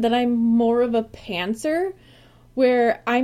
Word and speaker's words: pantser: Yep, pantser okay.